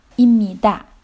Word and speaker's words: 입니다: 입니다. 读的时候是입니다. 임 下面的这个收音是 p 变成了 m 所以要读成입니다. 입니다. 因为这个 p 跟后面的这个 nian 它们发生了作用，变成了 mium，这是一个音变规律，请大家注意。